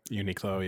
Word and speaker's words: Uniqlo: Uniqlo, yeah.